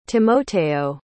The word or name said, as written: Timoteo